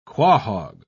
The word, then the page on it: quahog: British English